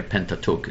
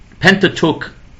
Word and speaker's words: Pentateuch: I'm using an English pronunciation here. Pentateuch. It's a Greek word, and so it's pronounced. I don't know exactly how it would be pronounced in Greek. I don't speak Greek, even though I had family that lived in Greece. But Pentateuch. Is like Pentagon, it's five, it means five, because it's the first five books of the Bible, the Torah.